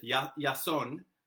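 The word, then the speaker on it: Yason (Ιάσων): And he takes on the Greek name Jason, although they would pronounce it Yason. I think Jason today in modern Greek is Yasonos or something like that.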